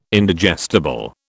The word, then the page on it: Indigestible: British English